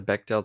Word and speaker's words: Bechdel: Oh, the Bechdel test.